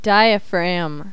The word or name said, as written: diaphragm